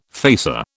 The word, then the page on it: facer: British English